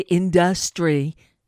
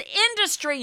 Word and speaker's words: industry: I wonder what they're doing, because this tremendous person in the industry... Who told him that the syllable is on the second, the accent is on the second syllable in industry? It's industry, you fucking moron.